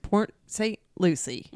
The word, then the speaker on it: Port St. Lucie: It's spelled Lucia or Lucia, but it's definitely just Port St. Lucie.